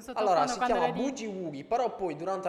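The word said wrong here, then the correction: Boogie Woogie: Ma mi sa che si pronunciava Bugi Wugi.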